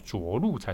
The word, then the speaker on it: 着陆: 我们昨天讲的这个软着陆啊，它其实发音有点错误哦，应该是着陆才对。